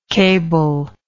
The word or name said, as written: cablelike